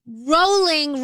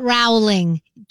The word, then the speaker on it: rolling: Oh, FYI, rolling rhymes with bowling, not Rowling. JK Rowling.